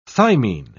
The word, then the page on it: thymine: British English